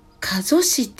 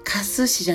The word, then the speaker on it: 加須市: 加須市って読むんですね加須市じゃなくって加須市にある八甲村さんというところで、えー、生まれてから死ぬまでに大切なたった 7 つのことと題してお話をさせていただきます。